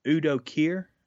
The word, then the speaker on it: Udo Kier: And this is one of the films in the five recommendations is a little known film called Blood for Dracula that Udo Kier.